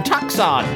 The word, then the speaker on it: Tucson: The question is, why is Tucson, why is the C silent? And, you know, it really ought to be quite obvious to you, because, Big Fatty, you don't know why, right? The C is silent. Well, I before E, except after C, and the C is silent in Tucson.